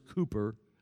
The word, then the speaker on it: Cowper: William Cowper, sometimes pronounced as Cooper, was an English poet.